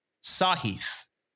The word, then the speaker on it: Sahith: Sahith is how you say it.